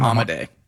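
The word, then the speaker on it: Amodei: Instead, the CEO, Dario, how do you say his last name? – Amade.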